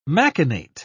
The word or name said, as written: machinate